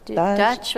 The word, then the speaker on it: Duch: Dutch without the T. – Really? – Yeah. How do you say D-U-C-H. – Without the T? – Dutch. Dutch.